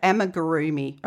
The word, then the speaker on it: Amigurumi: How do you say that? – Amigurumi. Okay, which is a fancy word. It's a Japanese word.